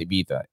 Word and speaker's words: Ibiza: Ibiza is actually pronounced Ibiza. It's an island in Spain, and it's just full of nightclubs.